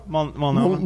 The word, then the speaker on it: Multnomah: Multnomah? – Multnomah.